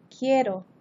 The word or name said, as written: quiero